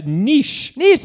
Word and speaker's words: Niš: Niš, that's right.